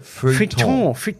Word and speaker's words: Feuilleton: Und dann habe ich gesehen, okay, es wird im Feuilleton positiv besprochen, was für eine Netflix-Serie. – So spricht man es aus. Das Feuilleton.